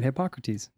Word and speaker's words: Hippocrates: You said it right, Hippocrates.